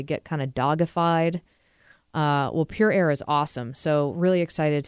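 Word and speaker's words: Pure Ayre: And then a new partner starting next week is Pure Air. A-Y-R-E is how you spell that.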